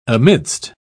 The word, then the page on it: amidst: British English